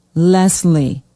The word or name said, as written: Leslie